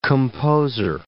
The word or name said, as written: composer